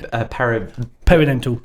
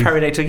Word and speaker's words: perinatal: But what are some common misunderstanding signs of parental mental health struggles? Did I say that right? It is, isn't it? – Perinatal. Thank you. Perinatal.